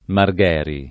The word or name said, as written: Margheri